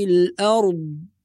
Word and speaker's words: الأرض: فلا نقول أرض كما ينطق البعض هذا خطأ، ولا نقول أرض بالقلقلة هذا أيضا خطأ، والصواب له ما في السماوات وما في الأرض وهو العلي العظيم.